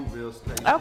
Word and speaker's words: Coogler: But shout out to Ryan Coogler. Is that how you pronounce it? – The director. He's black. – Coogler.